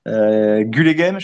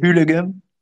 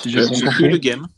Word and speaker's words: Gullegem: Gullegem, je ne sais pas si on prononce comme ça, je crois que c'est Hullegem. – Hullegem. C'est un G flamand, quoi.